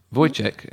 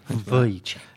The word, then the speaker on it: Wojciech: It is pronounced Wojciech, emphasis on the V. – Wojciech.